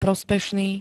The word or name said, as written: prospešný